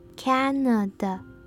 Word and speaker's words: Canada: 最后我们来看看加拿大怎么念，重音在第一音节，第一音节的母音 a 是发蝴蝶音 a，接下来的两个 a 都是念成弱化母音 a。 Canada。